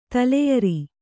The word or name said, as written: ತಲೆಯರಿ